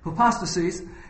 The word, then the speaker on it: hypostasis: The first one is pronounced hypostasis.